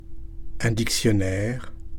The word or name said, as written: un dictionnaire